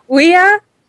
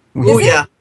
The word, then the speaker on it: Ouya: Ouya. – That's it. Ouya. Ouya. – Okay. Well. – O-U-Y-A.